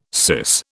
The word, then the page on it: syce: British English